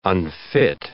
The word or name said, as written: unfit